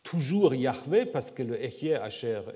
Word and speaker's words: Yahweh: disons la prononciation Yahweh est plutôt attestée en fait par des pères de l'Église, par Clément d'Alexandrie ou Origène également, qui l'attestent.